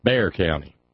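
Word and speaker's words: Bexar: Knew better than to pronounce the X. Bear County.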